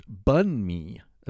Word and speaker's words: banh mi: Well, banh mi sandwiches is what they really do. And Vietnamese. – Oh, yeah. Guy Fieri. – There he is. – What is banh mi? Banh mi, pronounced bun mi, a Vietnamese traditional snack which provides comfort, sustenance, and complete satisfaction anytime, anywhere.